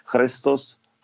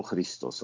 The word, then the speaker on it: Christos: Chrestos, to bol Christos. Len na západe ita vyslovovali ako eta. Čiže oni nepoužívali i, ale e. Čiže nepoužívali Christos, ale Chrestos.